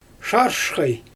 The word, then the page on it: ʃaaʃxaj: Xyzyl